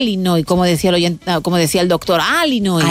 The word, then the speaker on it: Illinois: Pero es Illinois, o sea, si tú lo pronuncias bien y tienes buena dicción, es Illinois. – Illinois.